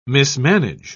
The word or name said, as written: mismanage